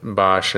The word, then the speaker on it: Bosch: How do you pronounce that, Bosch? – Bosch in the U.S.